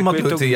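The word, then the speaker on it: Mathlouthi: Emil Matlouti, Zeg ik dat goed? Matlouti volgens mij. – Emil ja, Matlouti.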